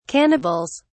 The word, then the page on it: cannibals: British English